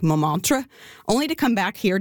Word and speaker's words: Montmartre: Montmartre. The T in the middle is silent, so do not say Montmartre, but rather Montmartre.